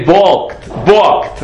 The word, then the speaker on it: balk: The Sifri refused, we would say balked, I think you don't pronounce the L, right? B-A-L-K.